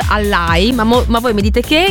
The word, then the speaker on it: Allai: Quindi Allai, questa è la pronuncia corretta.